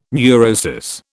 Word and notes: neurosis: British English

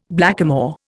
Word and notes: blackamoor: Indian

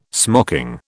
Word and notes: smocking: British English

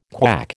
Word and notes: KWAC: American English

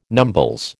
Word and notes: numbles: American English

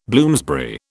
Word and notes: Bloomsbury: British English

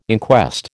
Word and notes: inquest: American English

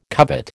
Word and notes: covet: American English

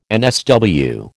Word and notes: NSW: American English

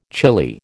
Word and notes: chilli: American English

